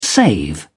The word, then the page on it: save: British English